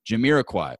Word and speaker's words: Jamiroquai: Jamiroquai.